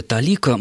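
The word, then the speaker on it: толика: Дарья спрашивает, он вложил в работу толику своей души или талику? Как правильно ставить ударение, откуда пошло это выражение? – Только талика, малая талика означает немного.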